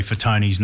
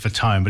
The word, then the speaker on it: Fatone: When I first saw Joey Fatone's name, I know he says Fatone, but it's Fatone, it's the way it's supposed to be pronounced.